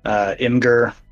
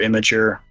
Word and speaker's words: Imgur: Yeah, I mean, I'm seeing a lot of anecdotal stories come through on Imgur, or Imgur, as some people pronounce it incorrectly.